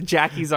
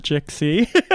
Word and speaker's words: JXCKY: That is Jackie, uh, spelled with an X, we should say. – Spelled with an X. J X C K Y. Not Jixie. Not Jexie. not Jexie. Jackie with a silent X.